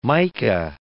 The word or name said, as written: Micah